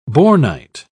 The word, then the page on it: bornite: British English